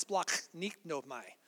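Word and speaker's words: splachnignomai (σπλαγχνίζομαι): It's splachnignomai. That's how you say it.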